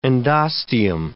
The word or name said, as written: endosteum